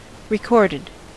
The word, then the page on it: recorded: American English